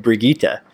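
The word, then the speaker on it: Brigitte: Also, Bridget, you're calling uh, Jeff from Overwatch uh, fart smeller because that is who said Brigitta. He pronounced it that way.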